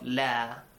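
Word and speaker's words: لا: إِنَّهُ لَا يُفْنِحُ الظَّالِمُونَ Make sure you pronounce the alif of la.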